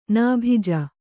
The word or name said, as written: ನಾಭಿಜ